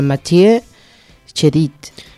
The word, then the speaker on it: Mathieu Chédid: Ara bé, us dic que el nom de l'artista és Mathieu, o Mathieu, en francès seria Mathieu Chédid. – Jo de Mathieu només conec el Jeremy Mathieu i ja no... – Però bueno, aquesta seria la pronunciació.